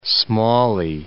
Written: Smalley

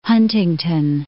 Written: Huntington

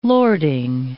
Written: lording

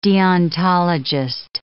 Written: deontologist

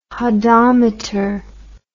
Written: hodometer

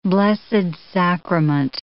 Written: Blessed Sacrament

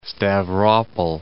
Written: Stavropol'

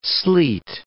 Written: sleet

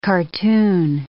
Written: cartoon

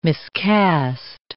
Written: miscast

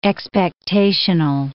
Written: expectational

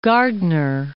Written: Gardiner

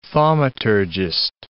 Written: thaumaturgist

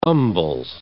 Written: umbles

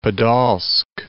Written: Podol'sk

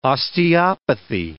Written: osteopathy

